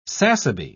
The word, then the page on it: sassaby: British English